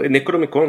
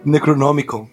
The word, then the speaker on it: Necronomicon: O Necromicron, não era o Necromicron? – Necronomicon.